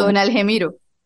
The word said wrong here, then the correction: Argemiro: Que realmente se escribe Argemiro, pero le han dicho todavía Algemiro.